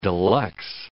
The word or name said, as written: deluxe